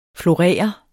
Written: florere

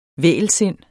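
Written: vægelsindet

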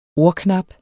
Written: ordknap

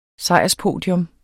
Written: sejrspodium

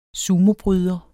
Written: sumobryder

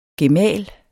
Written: GEMALEN

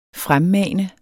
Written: fremmane

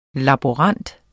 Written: laborant

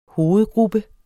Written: hovedgruppe

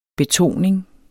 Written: betoning

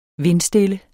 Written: vindstille

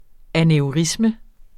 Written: aneurisme

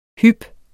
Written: hyp

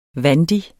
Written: vandig

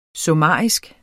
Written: summarisk

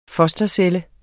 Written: fostercelle

